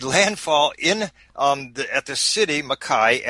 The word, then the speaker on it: Mackay: You make your way to Mackay. Is that how you say it? – That's right, Mackay, Australia, north of Brisbane.